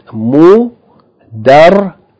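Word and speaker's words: mudarrisun (مُدَرِّسٌ): It should be pronounced mudarrisun. Mudarrisun. With shadda on the ra, so the ra is emphasized.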